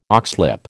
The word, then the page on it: oxlip: American English